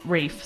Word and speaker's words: Ralph: Like, shouldn't he be called Ralph instead of Ralph? – No. I just think so, especially considering his kind of aristocratic leanings. It seems like a more aristocratic way to do it. – All right. Yeah, so I'm down for it. So we'll pronounce it Rafe.